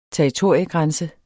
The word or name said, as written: territoriegrænse